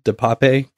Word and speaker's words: DePape: The one thing that makes me really lean towards this has got some sort of meme multiplier on it is, how do you pronounce his last name? DePap or DePepe? – DePap or DePepe. Yeah. I've heard different pronunciations. – That, well, you know where you know what I think in that regards. DePape, come on.